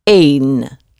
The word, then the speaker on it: een: And the word for one is also spelled the same, E-N, but pronounced een, een, een.